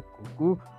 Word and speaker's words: Kuku: realmente se pronuncia cucú, ¿Qué, no qué.